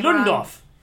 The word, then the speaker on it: Ludendorff: Ludendorff. – Wrong. Ludendorff, thank you.